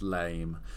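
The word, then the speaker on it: lamé: Actually, it's this gold lame, pronounced lame, pleated, almost chiffon, really very, very Mugler-esque kind of floor-length gown.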